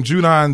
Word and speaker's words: Judon: So T-R-A-V as in Victor, E-L-E, travel E. And last name, Judon, J-U-D as in dog, O-N as in Nancy.